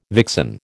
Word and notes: vixen: American English

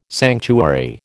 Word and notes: sanctuary: American English

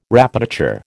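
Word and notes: répétiteur: American English

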